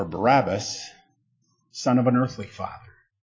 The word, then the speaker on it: Barabbas: What does Barabbas mean? Or let me pronounce it like the Hebrew would. Bar-Abbas. Son of Abba.